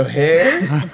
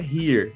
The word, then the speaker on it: hair: Eu falei, não, velho, hair, não é here. – Peraí, velho, o que você tá falando? – Ele, não, na Nova Zelândia é here, não é hair.